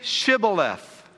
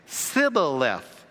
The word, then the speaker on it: Shibboleth: And if he said no, then they would say to him, then say Shibboleth. And he would say Sibboleth. He could not pronounce it right.